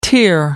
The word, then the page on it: tear up: American English